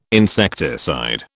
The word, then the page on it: insecticide: British English